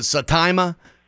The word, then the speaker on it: Saitama: And is it Saitama? Is that how you say it? – Yes.